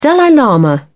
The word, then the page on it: dalai lama: British English